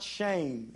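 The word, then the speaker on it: shame (שם): The meaning of the word name, it comes from the Hebrew word, believe it or not, shame is how it's pronounced. It's spelled S E M, but it's actually pronounced like we pronounce or, or spell our word shame. It's S E M, but it's pronounced shame, right?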